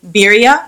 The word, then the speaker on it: Bria: Like, what is the correct pronunciation of that? – All right, so the accepted pronunciation is Bria. – Bria? – Right.